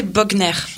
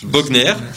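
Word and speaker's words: Boegner: C'est donc à l'espace protestant Marc Bonnier, je sais pas si je prononce bien. – Je crois que c'est Bogner. – Bogner.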